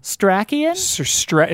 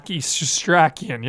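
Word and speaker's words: Setrakian: Sir Strachian is the old man. – Sir Strachian? – It's like S-E-R. It's really hard to pronounce. – One word? – One word. – Sir Strachian. – Strachian, yeah.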